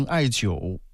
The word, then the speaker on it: 艾灸: 嗯，这个很好治，这个就是中医治疗啊，就中医治疗的方向，艾灸啊，艾灸。 – 有拔罐是吧？ – 哎，不是拔罐，没跟你说拔罐，跟你说的是艾灸，热敷。 – 也是一个针灸，是针灸。 – 就是按照你们的发音，就是艾灸，灸针灸艾灸，但是这个音它是三声艾灸。